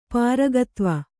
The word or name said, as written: ಪಾರಗತ್ವ